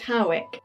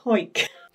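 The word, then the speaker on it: Hawick: Now, some people pronounce it Howick, but a quick Google has taught me that people from Hoyk tend to call it Hoyk.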